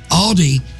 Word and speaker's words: Aldi: Well, Aldi had, or Aldi, whatever, how do you say it? – Aldi.